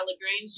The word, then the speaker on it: LaGrange: Yeah, LaGrange, Georgia.